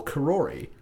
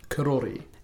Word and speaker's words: Karori: I mean, I grew up in what I used to call Karori. And I learned the other day that it's actually pronounced, and look, I'm a apologist for butcher this, Karori.